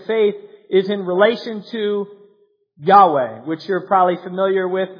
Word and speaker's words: Yahweh: Yahweh is the most likely translation. In past, had been thought to be translated Jehovah. But don't get all hung up on the pronunciation. Sometimes people get weird about that. That you can use Jehovah if you want.